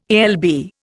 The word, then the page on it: alb: Indian